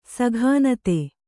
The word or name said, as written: ಸಘಾನತೆ